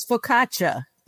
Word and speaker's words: focaccia: Am I saying that right? Who's Italian? Nobody. – You're gonna do better than I am. – My mother is purebred Italian. Okay, it's focaccia.